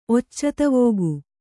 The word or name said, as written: ಒಚ್ಚತವೋಗು